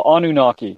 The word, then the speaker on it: Anunnaki: Well, Anunnaki, yeah.